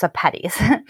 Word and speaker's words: Sepetys: It rhymes with spaghetti, so I think it's Sepetys.